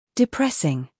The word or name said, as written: depressing